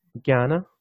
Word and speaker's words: Jnana: just so the listeners know, the title of this chapter is it Jnana? Am I saying that right? – Jnana.